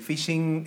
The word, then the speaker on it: phishing: El phishing eh, se escribe, en este caso es eh, phishing P-H-I-S-H-I-N-G, eh, y está escrito de tal forma que fonéticamente en inglés coincida con phishing.